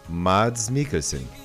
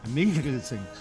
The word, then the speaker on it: Mads Mikkelsen: Ah, por favor, como é que é a pronúncia aí? Por favor. – Mads Mikkelsen. – Okay. Mikkelsen. – Mikkelsen.